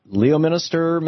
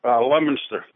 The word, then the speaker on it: Leominster: Leominster, Massachusetts. I know that's not how it's pronounced. How do you say your town? – Leominster.